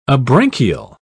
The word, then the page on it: abranchial: British English